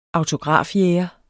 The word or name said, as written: autografjæger